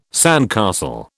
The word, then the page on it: sandcastle: British English